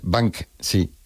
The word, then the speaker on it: Banksy: Es Banksy.